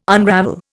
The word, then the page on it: unravel: Indian